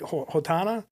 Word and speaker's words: Ohtani: Ohtani? – Yeah, Showtime.